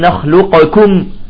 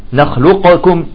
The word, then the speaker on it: نخلقكم: قال العلماء ثقيلة على النطق، ثقيلة. طيب قال العلماء، طيب حرف الاستعلاء قوي، فيجب أن لا يأكل الكاف الاستعلاء، فيبقى الاستعلاء بدون قلقلة. فنقول ألم نخلقكم، لاحظت رائحة القاف، ألم نخلق، لاحظت نخلق، بعدين تقول كاف، نخلقكم.